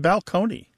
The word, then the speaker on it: balcony: Place on a stage that you're going to look down upon the seats used to be pronounced balcony.